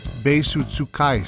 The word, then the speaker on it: Beisutsukai: Today I'll be reading the first three chapters of Eliezer Yudkowsky's Beisutsukai series. Beisu is the Japanese pronunciation of beis, and tsukai is the Japanese word for user. So it is the Beis User series.